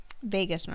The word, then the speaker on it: vagus: Vagus nerve. – Vagus. – Wait, no, that's how you pronounce it, is vagus nerve.